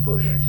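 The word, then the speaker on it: Fourche: It's a French name way back there, but it's F-O-U-R-C-H-E, and that's pronounced Push.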